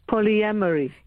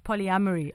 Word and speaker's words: polyamory: Polyamory. – Yes, polyamory.